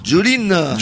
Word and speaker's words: Jolene: Jolene. – Jolene, voilà.